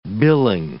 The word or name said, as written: billing